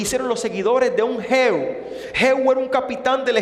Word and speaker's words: Jehú: o Jehú, lleva el acento en la O, viva el rey Jehú.